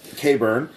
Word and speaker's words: Khaybryn: Caber or K-bar? – Caburn. That's spelled K-H-A-Y-B-R-Y-N. – Okay.